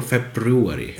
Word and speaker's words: February: Do you say February or February? – No one says February. It's spelled that way. Welcome to English.